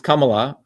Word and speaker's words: Kamala: Okay, as you can see in this title, we're saying Dr. Shiva versus Kamala. And that's how you say it, Kamala, with the Tamil Indian accent, not Kamala. It's Kamala. Okay, everyone try that.